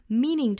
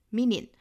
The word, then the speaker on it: meaning: Meaning 这个词呢，很多南方同学要重点的练习啊，因为这个词呢，很多同学会读成 meaning, meaning, 也就是把后元音给省掉了，给吞掉了。那要注意是 meaning, 尤其后面的这个 ning, ning 的发音，很像我们汉语里面这个宁静的宁。